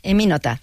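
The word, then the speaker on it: Emmy Noether: Te concedieron hace unos años el premio Eminether, ¿se pronuncia así? – Eminota, sí, es alemán.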